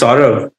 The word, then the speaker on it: Saurav: It's Saurav.